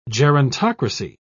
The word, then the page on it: gerontocracy: British English